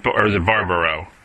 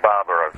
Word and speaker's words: Barbaro: Is it Barbaro? – It's actually Barbaro. – Barbaro, excuse me. I knew I was going to say the name wrong.